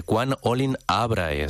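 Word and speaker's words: Quam olim Abrahae: Quan Olim Abrae, esa es la pronunciación correcta, como le prometiste a Abraham y a su descendencia.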